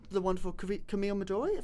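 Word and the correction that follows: Coduri: Camille Kudori. – Kudori. – Not Midori.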